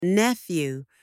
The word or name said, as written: nephew